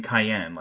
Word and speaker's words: Cayenne: In his early days, they spelled his name Cayenne, like C-A-Y-E-N-N-E, which was interesting, and pronounced it that way.